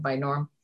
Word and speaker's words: binaural: I was just going to say that I'm familiar with the one, not the esophageal one, but the other one, the binaural.